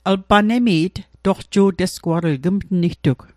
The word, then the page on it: toqju'tesguatl: Mi'gmaq